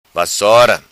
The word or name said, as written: Vassora